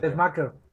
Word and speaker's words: Tessmacher: No, Tess, Tess, Tess. Pero, eh, ¿cómo es? Tessmaker, según yo. Tesmaker, ahí está.